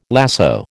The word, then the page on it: lasso: American English